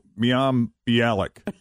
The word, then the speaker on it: Mayim Bialik: I always mess up her name. I always look at it, and I never know how to say it. Mayim Bialik. – Oh, Mayim Bialik. Mayim Bialik. – Yeah, it's Mayim.